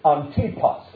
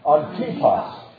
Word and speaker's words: Antipas: And did not deny my faith, even in the days, and you pronounce his name literally in the Greek, it's Antipas. Can you say that with me? Antipas.